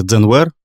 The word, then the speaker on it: Zenware: Вообще, многие не очень понимают, как это э, произносится, когда видят само название. На самом деле, это аллитерация сокращение, потому что первоначальное э, название бренда Zenware, два слова, mm-hmm. да? Zen, как э, дзен-буддийская традиция, переводится глубокое сосредоточение.